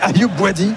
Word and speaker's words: Bouadi: Je me disais c'est qui Ayoubi, je regardais ma fiche, je me dis c'est ça, j'avais besoin d'un coup de brumisateur. – Il manquait une syllabe au milieu, quoi.